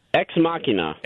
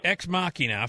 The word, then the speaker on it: Ex Machina: Ex Machina. – Ex Machina, $5.4 million.